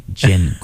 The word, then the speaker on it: Jin: It's like a not hard G Jin, Greg Jin.